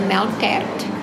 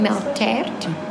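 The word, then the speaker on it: melktert: Other than that, we do the milk tart, which is a melt tart in Afrikaans.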